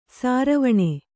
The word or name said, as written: ಸಾರವಣೆ